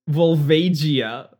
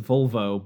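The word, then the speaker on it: Volvagia: Because the word vulva, I mean, V-O-L, so it's vulvo, but like, come on.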